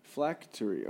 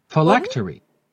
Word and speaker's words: phylactery: Take your time. – Flacatery. Okay. – Phylactery. – Oh, phylactery.